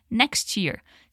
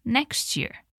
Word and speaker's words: next year: Olha só a pronúncia, que sai toda juntinha. Next year. Tenta repetir comigo. Next year.